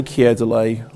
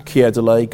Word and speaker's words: Keir Dullea: I say Keir Dullea, I use that pronunciation because Noel Coward was once said to have said, Keir Dullea, gone tomorrow.